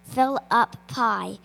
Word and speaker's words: Philippi: One time, Paul was in the city of Philippi, which is pronounced like you want more dessert. Fill up pie.